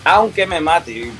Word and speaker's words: Aunque Me Mate: And if we could dig into that, like where that comes from, and forgive me if I butcher this, Aunque Me Mate. – Yeah, Aunque Me Mate, you got it perfect.